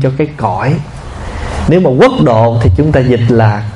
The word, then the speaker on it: quốc thổ: Quốc độ là mình đọc là quốc độ, chứ đúng chữ chúng ta phải đọc là quốc thổ.